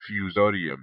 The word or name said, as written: Fusarium